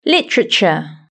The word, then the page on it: literature: British English